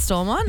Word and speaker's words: Stormont: Is that how you say it, Stormont? – Yeah, go with that.